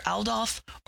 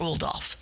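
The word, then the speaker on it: Oudolf: And you can pronounce his name either Audolph or Audolph. But his first name is Pete, even though it's spelled P-I-E-T, it's Pete.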